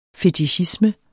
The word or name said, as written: fetichisme